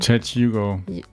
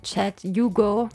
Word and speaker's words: Chad Hugo: Oder wie würdest du das auf Englisch aussprechen? – Chad Hugo. – Chad Hugo. – Hugo.